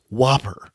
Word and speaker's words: WOPR: And the computer they're going to put it into the hands of is called Whopper, which is spelled in all caps, W-O-P-R.